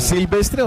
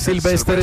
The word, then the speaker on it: Sylvester: ¿Pero es Silvestre o Sylvester? Él es Sylvester Stallone.